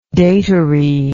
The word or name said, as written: datary